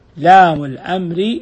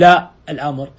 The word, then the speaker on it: لام الأمر: شوف لام الأمر، ما نقول لا الأمر، إنما لام الأمر، لأن حرف واحد، الحرف واحد ينطق باسمه كاملا، لكن لا الناهية حرفان، الحرفان ننطق بهيئتها التي أمامنا. فمن الخطأ تقول ولام الناهية، لا ما في شيء اسمه لام الناهية، لا الناهية. ومن الخطأ أن تقول لا الأمر، ما في لا الأمر، لام الأمر.